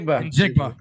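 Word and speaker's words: Njigba: Najigba.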